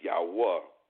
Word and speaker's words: Yahuwah: We use the proper pronunciation of Yad-Heh-U-Heh. That is the spelling of his name, for his name is Yahuwah.